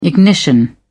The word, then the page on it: ignition: British English